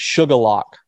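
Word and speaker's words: Shuqualak: Sorry, I mispronounced it the first time. It's Shukalac.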